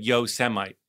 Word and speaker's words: Yosemite: And he pronounced Yosemite, he was reading a speech off a prompter that I'm sure he had never looked at, as Yosemite.